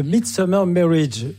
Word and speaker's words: The Midsummer Marriage: C'est The Midsummer Marriage. J'ai mal prononcé tout à l'heure.